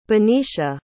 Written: benecia

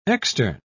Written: extern

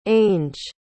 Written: ange

